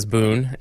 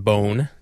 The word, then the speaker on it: Boon: Spelled B-O-O-N, almost universally mispronounced by us Americans as Boone. It is pronounced Bone.